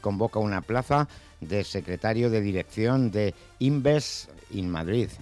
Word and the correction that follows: Invest in Madrid: Lo he pronunciado fatal, ¿no? – No, Invest in Madrid.